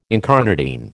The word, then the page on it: incarnadine: American English